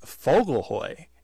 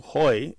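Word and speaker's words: Vogelheu: The Swiss-German dish is Fogelhoy, and it's spelled, believe it or not, with a V, because the V sounds like an F. V-O-G-E-L-H-E-U. And it means bird's hay. Vogel means bird, and H-E-U, hoy, means hay.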